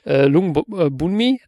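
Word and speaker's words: Lung Boonmee: Witzigerweise kann man den aber, also den kann man nämlich halbwegs aussprechen, würde ich sagen, weil Lung ist ja Onkel, das kennt man ja auch aus dem Hongkong-Kino, das ist ja auch immer Lung. Lung Bunmi, das ist korrekt und dann hast du halt irgendwie Raluek Chat, also vermutlich wird sich das irgendwie so ergeben.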